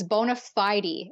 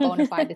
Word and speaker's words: bona fide: It's bona fide in British and Canadian pronunciation, not bona fide. It's the American one.